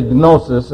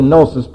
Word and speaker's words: gnosis: By the way, this is P-R-O-G-N-O-S-I-S. P-R-O-G-N-O-S-I-S. Now, we say gnosis. It's actually gnosis in English. It's gnosis in Greek because there are no silent letters in the Greek language. You pronounce every letter.